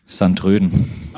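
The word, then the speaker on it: Sint-Truiden: Wie spricht man diesen Verein aus? – Sandröden.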